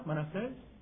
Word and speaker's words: Manasés: Lee la historia de Manasés, ¿así se pronuncia? Manasés. Qué terrible los pecados que cometió este hombre.